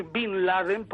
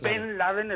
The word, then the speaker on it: Bin Laden: y no el anglicismo de decir Bin Laden, porque lo imitamos de los americanos. Claro. Ben Laden es el hijo de Laden. Y Bin Laden es los americanos, norteamericanos, que la E la pronuncian I. Entonces, mal, por nuestra parte.